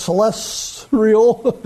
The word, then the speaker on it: celestial: I put an R in there. I didn't know until I looked in the dictionary that there was no R in celestial. So it's celestial.